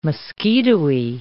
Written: mosquitoey

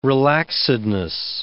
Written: relaxedness

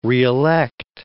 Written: reelect

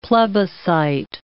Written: plebiscite

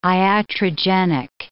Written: iatrogenic

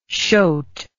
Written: shote

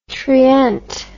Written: trient

